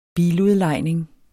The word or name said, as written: biludlejning